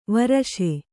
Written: ವರಶೆ

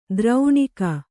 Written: ದ್ರೌಣಿಕ

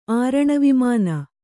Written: ಆರಣವಿಮಾನ

